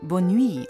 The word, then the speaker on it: Bonne Nuit: Bona Nuit kenne ich nicht. – Das spricht man Bonne Nuit und das ist französisch und heißt auch wieder Gute Nacht.